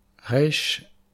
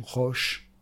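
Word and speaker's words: resh (ריש): Resh, aleph, shin, qui veut dire la tête, qui se prononce resh ou roche.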